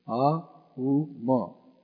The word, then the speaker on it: Aum: Actually, it's made up of three letters. A, U, Ma. Aum, A-U-M.